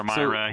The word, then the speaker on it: Myra: The True Life and Trials of Nicholas of Myra. Am I saying that right? – Sure. No, that's just fine. Mm-hmm. Myra or Myra, so, hear it either way.